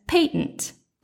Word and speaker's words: patent: I said to him that the word patent could not be pronounced as patent. Well, it turns out we were both right. Mine is simply the American pronunciation, while Victor's is the sophisticated British pronunciation.